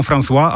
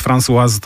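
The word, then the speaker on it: Françoise: Niektórzy mówią François, ale to imię męskie, natomiast François to jest forma żeńska.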